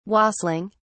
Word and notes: wassailing: British English

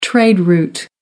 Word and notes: trade route: American English

